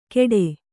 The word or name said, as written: ಕೆಡೆ